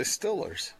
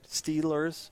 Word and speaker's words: Steelers: When they say Steelers in Western Pennsylvania, they say Stillers. – But the word is pronounced steel. – Okay. Yeah, I suppose. – I was about to say brick and steel. Steel. Right? Steelers. – Yeah. – It's not still. Okay. Not like a still.